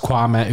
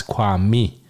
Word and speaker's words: Kwame: om att eh, Kwame inte vill kallas Kwame utan det uttalas Kwami.